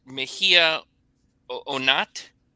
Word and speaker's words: Mejia-Onat: I pronounce it Mejia Onat, but that's Onat is not really my last name.